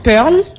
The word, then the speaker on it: Pearl: Alors c'est Pearl, comme Pearl en anglais.